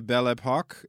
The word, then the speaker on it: Belle Époque: The Belle Époque era in France. Did I pronounce it correctly? – You did.